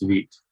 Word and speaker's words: Vite: As I understand it from Evan, it's Vite.